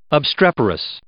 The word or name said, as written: obstreperous